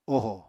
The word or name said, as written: oho